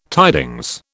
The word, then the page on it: tidings: British English